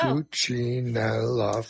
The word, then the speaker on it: Cuccinella: Cuccinella, four syllables.